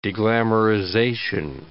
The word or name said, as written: deglamorization